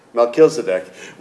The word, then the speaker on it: Melchizedek: Melchizedek was the priest of the God.